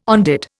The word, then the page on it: on dit: Indian